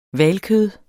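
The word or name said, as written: hvalkød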